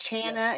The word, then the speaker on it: Chana: It's yes, Chana. Yes. It rhymes with like Charlie Chan, a hard CH. – Oh, Chana. – Some people try to say shush.